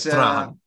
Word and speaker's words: Trajan: The last emperor who expanded the empire was Emperor Trajan, which is in English it's spelled like Trajan, but it's Trajan.